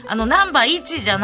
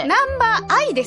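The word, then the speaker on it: Number_i: これ白組の初出場の、これ、ナンバー 1じゃなくて。 – ナンバー愛ですわ。 – あ、愛か。あ、これ愛と読むやな。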